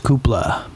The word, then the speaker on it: Cupola: I believe it's pronounced Coupla because it's like the little cupola in a house.